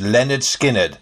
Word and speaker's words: Lynyrd Skynyrd: In August of this year, Lynyrd Skynyrd released their debut album, pronounced Lynyrd Skynyrd.